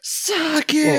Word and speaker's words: sake: Brian, it's sake. Pronounced sake.